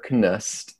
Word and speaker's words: Knust: T-E-X-T-S, by Jennifer Knust. And I understand she does pronounce the K, but it's K-N-U-S-T.